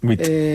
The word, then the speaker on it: nuit: Nuit.